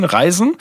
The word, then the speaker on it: reisen: Denn das ist der, der, der Zauberspruch, der im Prinzip für alles benutzt wird, unter anderem durch die Zeit zu reißen, äh, nicht reißen, reisen, der dann sozusagen äh, immer falsch ausgesprochen wird von dem Hauptdarsteller, weshalb er dann halt leider falsch reist.